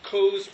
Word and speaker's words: Coase: And Coase, being an empiricist at heart, just a parenthetical here, Coase rhymes with Rose.